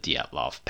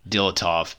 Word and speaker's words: Dyatlov: We were actually sitting around a campfire outside of my old apartment uh, talking about the Dyatlov Pass, which we at the time pronounced Dilatov Pass, and we have since educated ourselves.